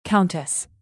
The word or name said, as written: countess